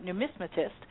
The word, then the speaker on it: numismatist: But he's really also known as being quite a numismatist.